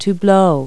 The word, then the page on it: blow: verb